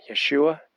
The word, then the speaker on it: Yeshua: It kind of looks like Joshua, but pronounced, you know, in Hebrew, Yeshua.